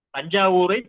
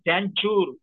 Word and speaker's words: தஞ்சாவூர்: தஞ்சாவூரை தஞ்சாவூர் என்று சொல்வது சரியானது. அதை தேஞ்சூர் என்று தேஞ்சூர் என்று ஆங்கிலேயர்கள் உச்சரிப்பது போலவே உச்சரிக்க வேண்டிய தேவையில்லை என்பது ஒரு கருத்தாக இல்லை.